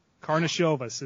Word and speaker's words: Karnišovas: Karsanov. Ooh, yeah, that's a hard one. That's a Lithuanian, right? That's that Lithuanian man. – But, yeah. Karnišovas is, I think, how most people are pronouncing it.